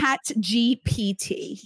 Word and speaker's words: ChatGPT: Is it GBT? Did I say it right? – ChatGPT. Yes.